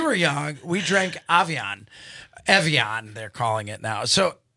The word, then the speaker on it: Evian: Anastasia, do you remember Avion? Have you seen Avion? – Evian.